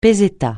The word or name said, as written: peseta